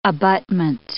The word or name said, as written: abutment